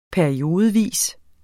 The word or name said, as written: periodevis